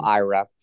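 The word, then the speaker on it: Aira: One, only because we keep saying Ira, and it's not overly intuitive how that's spelled. So in case you're curious, Tanya, that's A-I-R-A is how you spell Ira.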